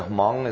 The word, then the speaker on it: Hmong: Is it the Hmong? Is that how you pronounce it? – Yeah. The H is almost silent.